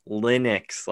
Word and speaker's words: Linux: Lennox. Like Lenny. – Yeah. – No, it's Linux, like L-I-N-U-X.